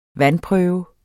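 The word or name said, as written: vandprøve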